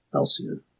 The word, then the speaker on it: Ellcia: Swords, sorcery, and technologies blend to form a mesmerizing tale of good versus evil in Elsia.